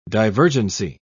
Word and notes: divergency: British English

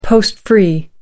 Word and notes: post-free: American English

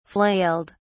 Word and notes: flailed: British English

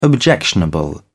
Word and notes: objectionable: British English

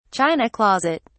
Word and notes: china closet: British English

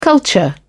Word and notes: culture: British English

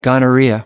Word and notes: gonorrhoea: American English

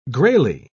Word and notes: grayly: British English

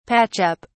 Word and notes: patch up: British English

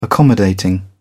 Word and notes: accommodating: British English

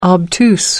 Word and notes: obtuse: American English

